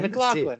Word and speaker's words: McLaughlin: No, McLaughlin. that's it, man. McLaughlin.